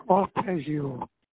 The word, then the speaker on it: arpeggio: And I understood that they said something, was it a preggio? I don't even know if I'm saying that right. What is that, the guitar arpeggio? – Arpeggio. – Oh, arpeggio, okay.